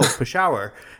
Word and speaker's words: Peshawar: And I remember someone running into the newsroom and saying, how do you pronounce this word? This city in Pakistan called Peshawar? And I'm like, no, Peshawar.